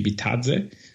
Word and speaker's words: Bitadze: I think it's Bitadze. – Yeah, that sounds right. – Something like that, because you don't pronounce the E like in the American way. So it should be Bitadze.